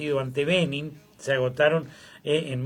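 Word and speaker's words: Benín: Benin. Vamos a buscar, Benin, porque no sé. – Con B larga y acento en la I.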